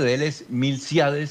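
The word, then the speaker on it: Milcíades: Sí, Julio César, el nombre de Longas Zapata es Milciades, con acento en la I de sí, Milciades.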